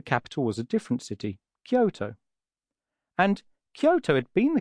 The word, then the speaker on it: Kyoto: Now, Kyoto in Japanese is pronounced as Kyoto.